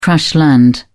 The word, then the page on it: crash land: British English